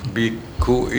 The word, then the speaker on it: biku: Oh, biksu itu bacanya biku, Pak. – Biku. – Biku. Nah, biku. – Ada juga kok biku. Ada juga yang aliran lain biksu. Kita biku.